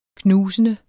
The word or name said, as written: knusende